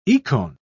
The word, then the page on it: ikon: British English